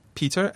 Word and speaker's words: Peter: Remember, my name is Peter, and the Japanese pronunciation would be Pita and Kazunori.